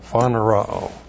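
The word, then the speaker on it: phaneroo: Phanerao is P-H-A-N-E-R-O, long O, phanerao.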